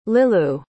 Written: lilou

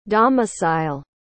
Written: domicile

